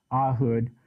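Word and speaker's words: Ehud: Ahud is an interesting character, and I guess that's how you pronounce his name, Ahud. It doesn't look like Ahud, does it? An A and a hood. But that's how it's pronounced, Ahud.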